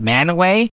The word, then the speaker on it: Mantua: And then there's another town, they call it Manaway, but it's spelled Mantua, M-A-N-T-U-A. I think it is, but they call it Manaway.